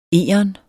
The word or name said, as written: EGERN